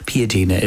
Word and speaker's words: Piadina: So Piadina. – Piadina is how they pronounce it.